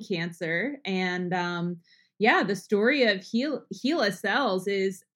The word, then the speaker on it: Hela: You know, for our listeners, before we started to record, I was like, how do I pronounce it? It's Hela. And she said, no, it's Hela, like Henrietta.